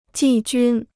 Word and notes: 季军: jì jūn

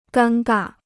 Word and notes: 尴尬: gān gà

